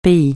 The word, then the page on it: pays: francais